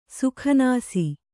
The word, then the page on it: ಸುಕನಾಸಿ: sukanāsi